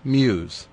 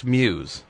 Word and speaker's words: Mews: M-E-W-S. – Muse? – Is that how you say it? – Newbridge M-E-W-S. Muse. – I've been saying it Mewis for my entire life. – Well, it's Muse.